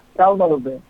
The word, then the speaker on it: Thalma Lobel: Am I saying that right, Thalma? – Yeah, Thalma Lobel. Yeah.